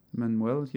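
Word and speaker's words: Emmanuelle: It's got I cannot pronounce her name, Emmanuel? – Manuel, yeah.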